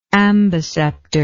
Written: amboceptor

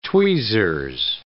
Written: tweezers

